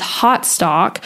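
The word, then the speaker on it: Hautestock: So two places I would recommend you look is Hotstock, which is spelled H-A-U-T-E stock.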